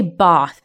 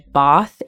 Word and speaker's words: Bath: Well, I should say Bath, because this is an interesting thing about the way people pronounce the word Bath in the UK. Mm-hmm. So people traditionally from the south of England will say Bath.